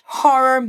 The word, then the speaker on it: horror: Essa palavra, horror, começa com H. Por isso a gente faz o som de h, h. Tipo quando você escreve ha, ha, ha. Repete aí. Ha. Ho. Boa. Na segunda sílaba tem dois R's, ou seja, hora de liberar o interior do Paraná ou o interior de São Paulo de dentro de você. Puxa esse R.